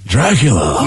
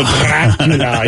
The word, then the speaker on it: Dracula: Dracula. – No, Dracula. You gotta get that double. – You gotta roll your R's almost.